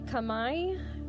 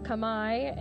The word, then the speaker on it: Khmer: The group though is called the Khmer or Khmer in Cambodia, but it's spelled K-H-M-E-R. So I don't know how you would think that was pronounced, but I certainly was not going to pronounce it the right way.